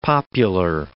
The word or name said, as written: popular